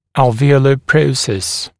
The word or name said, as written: alveolar process